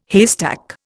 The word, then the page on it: haystack: Indian